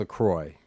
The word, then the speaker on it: LaCroix: I called it before just to find out if it was pronounced LaCroix. – Or what did they say? – Well, I got a an answering machine, and it's pronounced LaCroix.